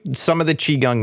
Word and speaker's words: Qigong: And oh, like so that's how you pronounce it. Qigong.